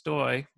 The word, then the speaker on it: Stoj: People can look me up by my handle, which we alluded to my name is last name is pronounced Stoj, but it's spelled S-T-O-J. The J is like a Y. And so my handle on Twitter and Facebook and Instagram is actually Stojboj, S-T-O-J-B-O-J.